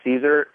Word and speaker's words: Cesar: My name is Cesar Rodriguez. If you throw that in Google, even if you spell my name wrong, it still might pop up. But my name is spelled C-E-S-A-R, the letter L. For my middle initial, and then Rodriguez, R-O-D-R-I-G-U-E-Z, .com.